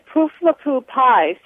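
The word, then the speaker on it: Pooflapoo pie: And the name that we had for it in our family, this is my question, is Poof-la-poo pie. – Say that again. – One more time? – Right. Poof-la-poo pie. So you would spell it P-O-O-F-L-A-P-O-O, pie.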